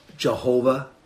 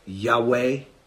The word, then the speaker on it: Yahweh: Y, H, W, H. Okay? Sometimes in older, it's Y, H, V, H. That we pronounce Yahweh.